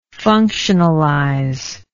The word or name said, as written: functionalize